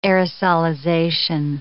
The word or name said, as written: aerosolization